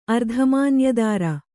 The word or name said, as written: ಅರ್ಧಮಾನ್ಯದಾರ